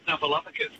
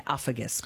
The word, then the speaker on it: Snuffleupagus: How do I say it? Oh, well, with a bit of practice, he was my favourite character back in the day. But, Snuffleupagus. – Well done. – I thought it was, Uffagus.